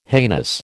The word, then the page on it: heinous: American English